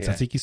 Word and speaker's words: tzatziki: It's like tzatziki sauce or whatever. – Yeah, tzatziki sauce.